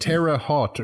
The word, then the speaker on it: Terre Haute: I think it's like Terre Haute or something.